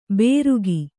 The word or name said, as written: ಬೇರುಗಿ